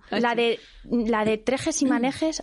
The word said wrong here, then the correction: tejes y manejes: a ver, corrige, ¿sí? ¿Lo he dicho bien? – Tejes y manejes.